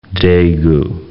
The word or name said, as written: Daegu